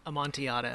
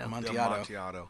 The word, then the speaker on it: Amontillado: Amontillado. – Amontillado.